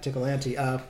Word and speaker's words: Ticklante: Ticklante. – That's awesome. – Take Tickle and Vigilante and slam them together.